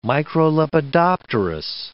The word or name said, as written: microlepidopterous